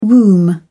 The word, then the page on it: womb: British English